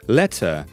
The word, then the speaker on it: let her: It almost sounds like letter or letter if you pronounce it a more American way. Letter, letter, let her go, let her go. It's never really pronounced like separately, let her. You end up saying letter, letter.